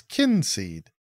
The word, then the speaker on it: Kinseed: Reminds me, just quick little tangent, Kinseed, the game, in their settings has a little button that tells you how to pronounce it. Like, that's nice. – You click the button and it says Kinseed.